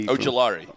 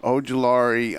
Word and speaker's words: Ojulari: Ojulari. – Ojulari.